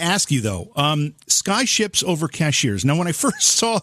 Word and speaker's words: Cashiers: And it's pronounced Cashiers, but it's like gotcha. – Somebody that works at Walmart's.